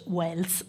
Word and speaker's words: Wells: Herbert George Wells, per l'appunto, che si pronuncia uguale a Orson Welles, però era chiaramente un grande scrittore, ed è rimasto famoso per aver scatenato il panico descrivendo appunto un'invasione aliena.